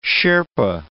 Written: Sherpa

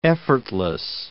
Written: effortless

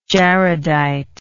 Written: jaredite

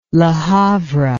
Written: Le Havre

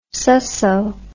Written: susso

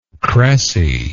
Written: cressy

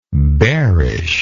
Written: bearishness

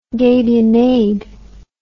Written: gabionade

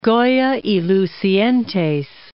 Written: Goya (y Lucientes)

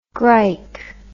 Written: grike

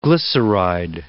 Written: glyceride

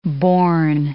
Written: bourn

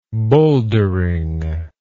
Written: bowldering

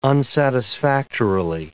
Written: unsatisfactorily

